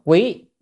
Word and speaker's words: quý: Bây giờ chúng ta đến cái mục tiếp theo, cái mục này, cái chữ này đọc là quý, quý nhị.